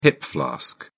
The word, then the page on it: hip flask: British English